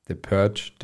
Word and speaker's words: The Purge: Aber The Purge, The Purge.